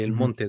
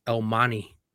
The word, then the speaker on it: El Monte: So the T, one of the things that I fucking hate, and I still hate it till today, there's a city called El Monte. Literally, El Monte. Mm-hmm. That's the way. But the way they pronounce it here in Cali is El Mani.